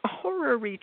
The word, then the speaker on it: horary: So horary chart, despite the sound of the name, is spelled entirely different. H-O-R, whatever, A-R-Y, horary chart.